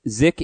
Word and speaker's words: Zych: Zick is, it's spelled Z-Y-C-H.